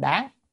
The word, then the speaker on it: đáng: Đương ở chỗ này không bắt đầu là đương, phải đáng, âm đáng mới đúng.